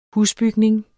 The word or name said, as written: husbygning